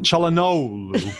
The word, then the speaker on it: Çalhanoğlu: Çalhanoğlu. It's a bit of a silent G.